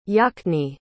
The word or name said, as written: yachne